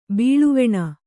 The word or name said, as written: ಬಾಳ್ವೆಣ